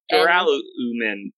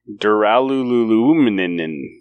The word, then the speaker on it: Duralumin: But you can see he's starting to set this up with Dur-al-umin. – Wow. – I can't say it. – You had all the syllables there. – Duralumin. – Right. – Duralumin. – Duraluluminin.